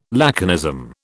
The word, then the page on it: laconism: British English